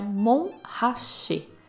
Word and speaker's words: Montrachet: Os franceses não pronunciam o T, então é Montrachet e significa um monte onde nada cresce, onde não se produz nada.